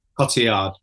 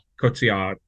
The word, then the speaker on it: Cotillard: I can never pronounce the second name, Marianne Cotillard. – Cotillard, yeah.